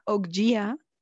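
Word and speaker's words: Okja: Mira, yo traje a nuestra mesa el día de hoy la película de Ogya o, como creo que se pronuncia adecuadamente en la película, Oggya,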